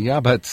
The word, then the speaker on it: yabbuts: Yeah, the yeah buts. So this is it's spelled Y-A-B-B-U-T-S, but it's yeah but is what it is.